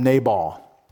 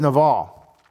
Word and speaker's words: Nabal: Preachers in the past might have called him Nabal, but the word is Naval, and it means fool. It has nothing to do with our military forces on the sea. N-A-B-A-L, pronounced N-A-V-A-L, Naval.